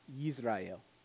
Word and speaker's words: Israel: In Hebrew, the name Israel would be pronounced Yisrael. And Jezreel would be pronounced Yisrael. They're homophones. They're homophones.